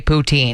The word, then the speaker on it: poutine: This is how they say you're supposed to say poutine.